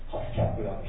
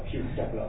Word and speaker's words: HUSCAP: 国大の機関リポジトリ、ハスカップ、 HUSCAP、みんな CAP じゃなくて CUP って書いてあるし、まあ、発音しづらい。実際に、中心でやっている人も海外に行ってこれの説明するときに、ハスキャップだ、ヒュースキャップだと言っているそうですから、まあ、信用ならんものです。